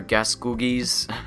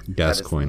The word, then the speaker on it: Gascoigne: And then there's that thing with Father Gascougnes. – Gascoigne.